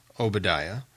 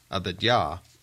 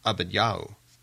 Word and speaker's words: Obadiah: Many pronounce it Obadiah, as we do today. However, the more correct pronunciation of his name would be Abadiah or Abadiahu.